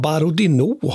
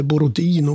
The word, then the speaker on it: Borodino: Om vi går vidare sen så tycker jag också att slaget vid det som ibland, nämligen som brukar väl uttalas ibland, Borodino har jag lärt mig faktiskt, eller Borodino, man kan ju välja där vad man är för språktradition.